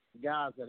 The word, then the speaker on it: Gaza: For the Record Radio, where the independent artist matters the most, I am your host, Flock Gaza. That's right, I said it. For those who don't know how to say it, the name is Flock, like a flock of birds. And Gaza, like the Gaza Strip.